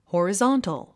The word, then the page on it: horizontal: hɔ`rəzɑ’ntl; ホォリゾントゥ